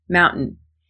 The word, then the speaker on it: mountain: It's totally okay to say mountain with a T in there. The only thing we want to avoid is saying mountain and pronouncing the A that comes after T. Mountain, mountain, both work.